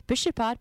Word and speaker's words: pixxispad: Pishipat, by the way, P-I-X-X-I-S-P-A-D. That's how you spell it.